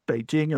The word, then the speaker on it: Beijing: Now, in fact, that je sound doesn't exist in Chinese. So you'll find a lot of people referring to Beijing as Beijing, and you'll find even native speakers of Chinese now using that Beijing pronunciation, even though that's just the jing of jingle bells. That's a sound that we have in English. So Beijing is, it's like jingle bells, right?